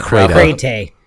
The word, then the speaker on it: Crata: I still can't remember how to say, how do you say it? – Cray-tay. – Yeah. – Cray-tay. Oh, hey.